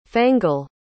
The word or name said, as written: fangle